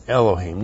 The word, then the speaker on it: Elohim: And then Elohim, Elohim, Aleph, E-L-O-H, long I-M, Elohim.